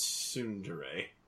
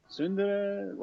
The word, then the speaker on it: Tsundere: Yeah, I love Raharu basically mispronouncing the word like sundry, sundere, like whatever, you're that.